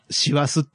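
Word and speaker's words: 師走: シワスっていうさ、坊さんが走るって書いてシワスって読む季節なのにさ、キリスト教のことやってんだぜ。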